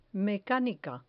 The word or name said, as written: mecanica